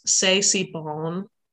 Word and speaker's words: C'est si bon: She's the first person to have sung the English version of the French songs C'est si bon.